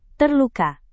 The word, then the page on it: terluka: indonesian